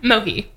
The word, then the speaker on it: Mohi: Mohi. – Okay. – As in no money, no problems with the third person singular masculine pronoun.